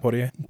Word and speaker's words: Poitier: Like, and so he talked about how that kind of slowly transitioned into finally seeing like Sidney, how do you say, is it Poitier? – Poitier.